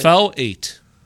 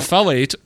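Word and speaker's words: fellate: November 2013, Charlie sent an abusive tweet to his ex-wife, Brooke Muir, inviting her to filet. – No, it's not filet. – F-I-L-E-T. – Facilitate. – Filet mignon is not spelled the same way. – Facilitate. – It's fel-ate.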